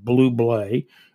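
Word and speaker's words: Bublé: His last name is pronounced Blublay.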